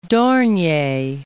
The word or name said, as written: Dornier